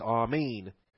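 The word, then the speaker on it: amen (ἀμήν): The eta is a long A sound, so it's not amen. It's amen.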